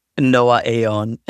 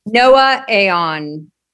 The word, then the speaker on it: Noaaon: Am I saying it right? Noah Anon, the brand name? – Noah Aeon. – Noah Aeon. So N-O-A-A-O-N.